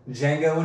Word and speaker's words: Django: Django and Jimmy. – Django and Jimmy. – Spelled the same way Django Unchained is. – The D is silent? – Exactly.